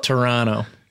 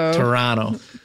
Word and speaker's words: Toronto: Turandot. – You're not saying it right. – Turandot? – Toronto. – Yes. – Okay. – Listen to him. – Don't hit that T and it's not a D.